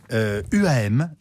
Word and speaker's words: UAM: UAM, je prononce correctement. – Oui, c'est comme ça.